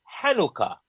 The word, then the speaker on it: Hanukkah: So that's where Chanukah is pronounced Chanukah, but is also used and pronounced Hanukkah.